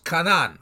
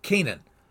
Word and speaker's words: Canaan: He curses Canaan, or as we pronounce it, Canaan.